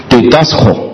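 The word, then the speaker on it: Didasco (διδάσκω): 教导它原文希腊文它读作 Didasco，这个词是什么意思呢？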